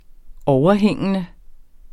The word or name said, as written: overhængende